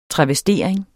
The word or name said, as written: travestering